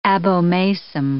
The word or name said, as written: abomasum